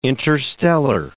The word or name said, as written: interstellar